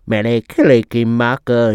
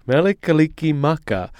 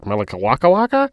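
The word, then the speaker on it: Mele Kalikimaka: Melikalikimaka. I'm probably not even saying it right, and I'm probably over-pronouncing it, but I wanted to get it right, because when I first read it, I was like, Melikawakawaka?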